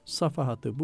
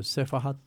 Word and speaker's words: Safahat: Siz safahatı sefahat diye söylerseniz, ki söylüyorlar maalesef, efendim, o zaman mana 180 derece değişir.